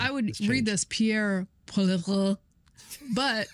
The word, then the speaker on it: Poilievre: I believe he says in the video we're going to play for you, Pierre Poilievre.